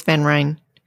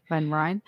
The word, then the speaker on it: Van Ryn: Mike Van Ryn and I don't know how to pronounce. I think it's Mike Van Ryn or Mike Van Ryan. – I think it's Van. It's Van Ryn. – Van Ryn, all right, mm-hmm.